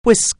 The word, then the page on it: whisk: anglais